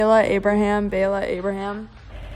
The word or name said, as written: Bayla Abraham